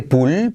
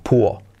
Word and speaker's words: pool: Cuidado, pool que no se dice pull, pull, pull, sino la L, letra clave, como muy arriba, puo, puo, puo. Como búho, un búho, pero con P, puo, puo, un búho.